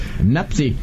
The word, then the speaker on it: Mpasi: No va a sonar muy conocido porque la pronunciación es Lionel Mapsi. – Qué capricho. Así parece un capricho. – Lionel destino. – Napsi. – Napsi. – ¿Cómo el apellido? – Mapsi. – Es M-P-A-S-I.